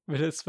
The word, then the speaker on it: Villads: Villads with a silent D.